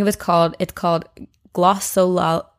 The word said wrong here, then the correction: glossolalia: glossolia. Mm, yeah, I knew I wasn't going to be able to pronounce that. It's called glossolalia, which is speaking in tongues.